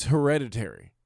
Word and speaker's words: Hereditary: Are you saying Heredity? – It's Hereditary. That's the name of the movie, Hereditary. – It came out a few months ago? – Yeah. – Am I saying it wrong? – Yeah, it has an R in it, Hereditary.